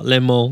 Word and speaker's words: Le Mans: Le Mans.